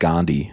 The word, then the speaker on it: Gandhi: it's actually pronounced Gandhi. – Oh, Gandhi.